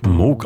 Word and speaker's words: Moog: Robert Moog, der eigentlich Moog ausgesprochen wird.